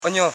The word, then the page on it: enyo: e.nyo